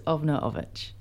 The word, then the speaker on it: Ovna Olvich: Over Olvich. – No, not quite. – I always mispronounce it, sorry. – It's kind of hard, I mispronounce it too, but it's Ovna Olvich.